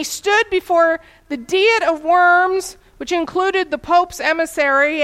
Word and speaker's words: Worms: At the Diet of Worms, and that's spelled D-I-E-T, and that is not the Diet of Worms. It is the Diet of Worms.